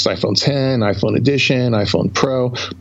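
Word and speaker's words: iPhone X: By the way, for the record, I called it iPhone, you know, Roman numeral X, pronounced 10, because OS 10 was always pronounced that way, even though it was an X, but that's another story.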